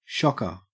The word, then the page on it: shocker: Australian English